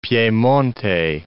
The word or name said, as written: Piemonte